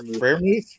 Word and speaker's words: Freiermuth: Freer Freiermuth.